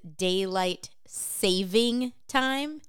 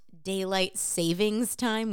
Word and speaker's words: daylight saving time: But I want to first start with, did you know that the proper way to say it is daylight saving time, not daylight savings time, which sounds more accurate? So you're wrong if you're calling it daylight savings time.